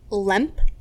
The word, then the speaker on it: Lemp: Limp. – Can you spell it? – L-E-M-P.